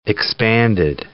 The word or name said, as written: expanded